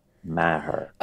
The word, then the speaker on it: Maher: Yeah, it's Christopher Lee Maher.